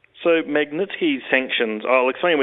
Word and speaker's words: Magnitsky: But the point I've got here is, and correct if my pronunciation is, a Magnitsky sanctions. Could you? – Oh, yes, Magnitsky, yep. Yeah. Oh, good, I got it right.